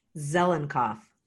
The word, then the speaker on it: Zelinkoff: Zelinkoff.